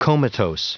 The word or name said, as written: comatose